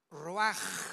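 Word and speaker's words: ruach (רוח): Y exhaló Isaac el ruach, que se tradujo espíritu, R-U-A-C-H, y se pronuncia en el hebreo ruach, como si fuera J, ruach.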